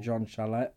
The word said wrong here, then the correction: Jean Chastel: Jean Chastel, uh-huh.